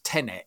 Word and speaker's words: Tenet: I think it's pronounced Tenet. It's T-E-N-E-T.